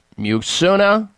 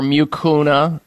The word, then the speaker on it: Mucuna: Mucuna or Mucuna, depending on how you pronounce it, Mucuna purines.